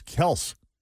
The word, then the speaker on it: Kelce: You know, we just had this conversation about Travis and Jason Kelce finding out that Kelce's not even how you're supposed to pronounce their last name. They weren't even pronouncing it right. Originally, it was Kels. – Yeah. K-E-L-S.